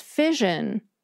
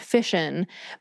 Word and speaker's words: fission: Second, there's fission. Personally, I pronounce this with an sh sound, fission, but most of the people I hear pronouncing it say it with the zh sound, fission. I'm guessing this is because the word is often used in the same context as the word fusion, and the two pronunciations are creeping closer together. And there's a precedent for this.